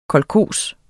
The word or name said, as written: kolkhos